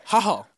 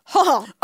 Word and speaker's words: Hohl: How do you say that? – H-O-H-L. – Oh, Hall. – Hall.